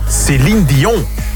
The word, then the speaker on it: Céline Dion: Céline Dion. – Céline Dion. Met een goede uitspraak ook.